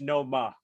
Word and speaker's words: Noma: That's my only requirement is that I'll we get a Nomo explanation. Update. And by the it, way, it's no ma. I'm a little concerned. Let's just get the pronunciation correct, friends.